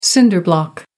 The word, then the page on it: cinder block: American English